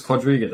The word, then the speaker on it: Quadriga: Yeah, so the story with like Quadriga, it is Quadriga, that's how you pronounce it.